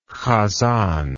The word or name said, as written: chazan